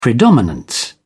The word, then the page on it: predominance: British English